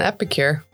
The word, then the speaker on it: epicure: If there's one thing Bruno is, it's an epicure. – I don't even know what that means. – I don't either. Did I say it right? – Yeah.